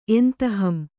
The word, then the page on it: ಎಂತಹಂ: kannada